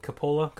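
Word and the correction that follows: Capela: is that how you say it? He's up too. I think he's a restricted free agent. So, I mean, they got some other moving pieces that, you know, they'd have to, Capella.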